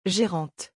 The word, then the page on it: gerante: French